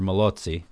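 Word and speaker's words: Mallozzi: Joseph Malozzi, or Malozzi, as it should really Malozzi. – Be pronounced.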